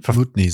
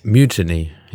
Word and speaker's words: Mutiny: Ich glaube Mutiny sagt man, oder? Du hast das letzte Mal so ausgesprochen, glaube ich. – Mutiny, ja. Also Mutiny. Die Betonung ist auf dem U.